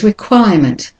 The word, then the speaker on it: requirement: The next word is requirement. Requirement. So it's requirement. So you've got two schwas there. Requirement. And the next word is departure. So the last syllable, T-U-R-E, that common ending, is always said as a schwa.